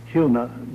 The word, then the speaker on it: Huna: That's spelled H-U-N-A. Sometimes it's mistakenly pronounced Huna, but it's not a long U. It's like a double O. It is Huna.